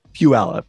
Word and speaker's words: Puyallup: Puyallup.